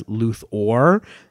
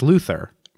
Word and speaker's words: Luthor: Yeah, well, I always think of Luthor because in Superman, the animated series, they used to tell the actors to really emphasize Luthor because, like, naturally you say Lex Luthor. – Right. – With an E-R, and it's obviously, it's spelled with an O at the end.